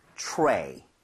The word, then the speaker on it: tray: T-R. 그 다음에 모음이 나오게 되면 치읓 비슷하게 발음이 될 수밖에 없습니다. 왜? R 발음 때문에. 그래서 입모양을 모은 상태로 tray를 하려고 노력을 해볼게요. 이런 느낌이에요. tray.